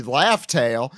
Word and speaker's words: Laugh Tale: People keep pronouncing this wrong. It's supposed to be Laugh Tale.